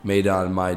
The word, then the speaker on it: Maidan: It's pronounced and spelled differently across multiple countries, so it's really Maidan.